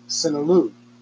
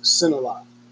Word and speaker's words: Cinelu: Or depending on how you look at the spelling on it, C-I-N-E-L-U, Sinilou. I believe it's pronounced Sinilot.